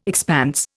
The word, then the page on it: expanse: Indian